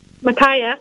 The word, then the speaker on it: Micaiah: I'm not sure if I'm pronouncing the prophet correctly. It's Micah or Micaiah? – Micaiah? – Micaiah. – Yeah.